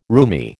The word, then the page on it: roomy: American English